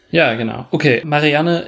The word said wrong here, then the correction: Marianne: ist, äh, oder Marianne, besser gesagt, ich habe das sehr deutsch ausgesprochen gerade, äh, ist Malerin und sie bekommt den Auftrag, Eloise zu malen.